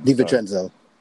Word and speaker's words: DiVincenzo: We've seen instances with Dante DiVincenzo. I might be saying his name wrong. – DiVincenzo. – Say that one more time. – DiVincenzo.